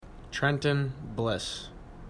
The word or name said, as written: Bliss